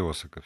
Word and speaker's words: Осака: Как по-японски не знаю, в русском варианте Осака все таки Вот из Осаки возвращаясь, потому что, мне кажется, в японском там ударение на последний слог.